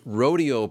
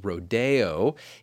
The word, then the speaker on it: Rodeo: As I said in our season preview episode, you may have heard the title of this ballet pronounced Rodeo. It was fashionable at one time to use the French pronunciation of this title, and it in fact still is acceptable. I'm going to stick with the American rodeo.